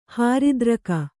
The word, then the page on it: ಹೃದ್ರೋಗಿ: kannada